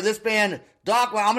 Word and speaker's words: Dakhma: The song name is Dakhma. It's D-A-K-H-M-A. D-A-K-H-M-A. Dakhma. – Sounds right.